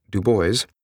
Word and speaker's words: Dubois: Robert Raymond Dubois, pronounced locally as Dubois,